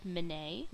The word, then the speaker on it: Menet: The pattern's called Menet, which is spelled M-E-N-E-T, and it's by a designer named Mindy Talick.